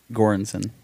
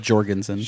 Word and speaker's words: Göransson: Ludwig Göransson. – Great. – I said Jorgensen. – Shout out to him. That correct pronunciation.